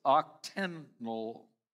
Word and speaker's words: octenol: In some Florida localities swarming with mosquitoes, officials are using a concoction of carbon dioxide and the chemical called octenol. It's O-C-T, anyhow, if you want to write it down for notes. O-C-T-E-N, octenol, O-L.